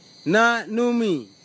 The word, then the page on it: nanumi: kwamera; Tanna